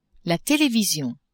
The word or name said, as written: television